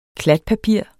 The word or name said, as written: klatpapir